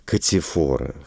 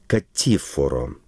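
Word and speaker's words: Catiforo: Я его называю, так как мы находимся в русском родийном пространстве, Катифора. Хотя итальянцы смещают ударение. По-итальянски это было бы Катифоро, что как-то нехорошо, наверное.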